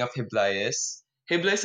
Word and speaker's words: Hibla: Yeah, because we're gonna talk about your product, and I'm interested to hear what actually inspired you to develop Hibla, if that's the correct pronunciation. – Yeah. – Yes, Hibla.